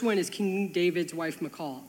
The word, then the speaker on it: Michal: I'm gonna pronounce it Michal because it's my little sister's name, and that's how we pronounce it. I know some of you guys will say Michael or Mikael or Michal, but we call her Michal, so I can't undo my brain.